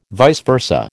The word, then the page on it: vice versa: American English